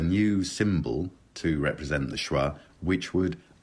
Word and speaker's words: schwa: The sound that you get in above. That sound is called a schwa. That's S-C-H-W-A.